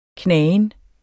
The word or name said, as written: knagen